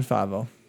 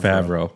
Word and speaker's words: Favreau: The character that was flying beside him, voiced by John Favreau. – John Favreau.